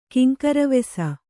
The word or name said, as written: ಕಿಂಕರವೆಸ